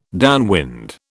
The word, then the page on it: downwind: British English